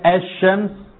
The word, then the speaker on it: الشمس: اي حرف يا اخوانا هيدغم في اللي بعده، زي الشمس كده الشمس، نطقنا الالف واللام دي ما تنطقتش، راحت فين؟ اضغمت، دخلت في الشين، فاللام ما تنطقتش.